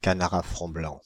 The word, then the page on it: canards à front blanc: France (Île-de-France); /ka.na.ʁ‿a fʁɔ̃ blɑ̃/